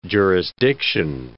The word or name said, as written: jurisdiction